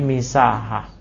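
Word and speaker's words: misaha (مساحة): Bacanya bukan masa tapi misaha.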